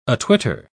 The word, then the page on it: atwitter: British English